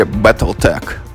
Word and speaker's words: BattleTech: Ну, вот насколько я помню, на Сеге там произносилось в игре Battletech. – Ну да, потому что все-таки корень-то тег, а не тех.